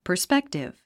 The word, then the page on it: perspective: pə r spéktiv; パァスペクティブ